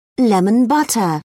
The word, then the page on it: lemon butter: British English